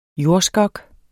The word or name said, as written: jordskok